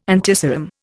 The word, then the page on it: antiserum: Indian